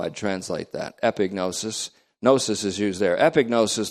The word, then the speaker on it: epignosis: Epi, E-P-I, then G-N-omega-O-S-I-S. Epinosis. And we used to pronounce it that way, but the G is actually pronounced in this, so it's epignosis.